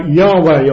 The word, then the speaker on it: Yahweh: The original was probably more likely pronounced something like Yahweh or Yahweh. But in English we tend to say Jehovah.